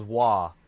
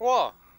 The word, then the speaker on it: Roy: R-O-Y becomes Wah. There was another one, though. – Patrick Wah.